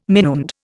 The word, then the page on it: minuend: Indian